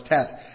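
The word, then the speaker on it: Teth: And if you leave the H there, it's not pronounced anyway. It's silent, so it's tet.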